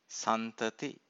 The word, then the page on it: santati: Pali